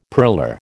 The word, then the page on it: purler: American English